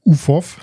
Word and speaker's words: UFOF: Und zwar hatten sie, ich will nicht sagen Durchbruch, aber das dritte Album, wie spricht man das aus? UFOF. – Ja, genau. Ja.